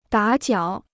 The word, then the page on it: 打搅: dǎ jiǎo